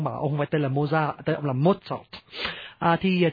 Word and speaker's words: Mozart: Tiếng Đức tên Mozart đọc là Mozart, nhưng mà người Việt Nam mình đọc theo tiếng Pháp đã quen là Mozart, mà nghe nó nhẹ tai hơn, thôi mình đọc là Mozart đi nhá.